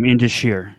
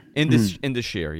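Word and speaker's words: Indus Sher: How do you say this? – That's the tag team, Indusheer. – Indusheer, yeah, there you go.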